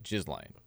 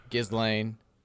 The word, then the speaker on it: Ghislaine: How's her name spelled? – G-H-I-S-L-A-I-N. – Oh, good Lord. – Ghislaine. He's saying it's G-Lane.